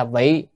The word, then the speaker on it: vị: Vị độ quần sanh, chữ vị ở đây có hai âm, một là vi hay là vị, ở đây phải đọc là vị.